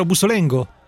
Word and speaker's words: Bussolengo: Ho detto bene, vero Bussolengo? – Perfetto, okay, sì, perfetto.